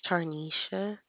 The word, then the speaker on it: Tarnisha: My name is Tarnisha. – Can you spell it for me? – It's T, as in Tom, A, R-N-I-S-H-A.